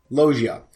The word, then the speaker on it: Loggia: Robert Loggia, is that how you pronounce his name? – Loggia.